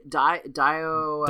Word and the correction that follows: Diogenes: I heard Diogenes.